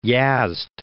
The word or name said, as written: Yazd